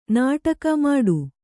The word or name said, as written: ನಾಟಕ ಮಾಡು